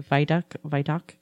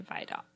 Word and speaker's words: Vidocq: VDOC. It's spelled, if you want to Google it, which it's fascinating. It's VIDOCQ. Right there. – I would say VDOC.